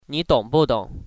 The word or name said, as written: ong